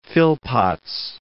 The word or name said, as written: Phillpotts